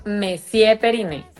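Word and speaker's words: Monsieur Periné: Pero para los que van a decirme después que así no se dice señor en francés, pues resulta que aquí lo pronunciamos a lo criollo, ¿o no, Catalina? – Así es, decimos Messier Periné.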